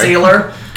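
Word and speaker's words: Saylor: Am I still afraid of Sailor? – No. – Okay. Salar. – Sorry. – Sailor. – Get it right.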